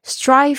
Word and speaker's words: strive: strive for，大家特别注意一下你们的 strive 的发音，这个 s t r i 变成这个 s d r i 这种声音呢、哦？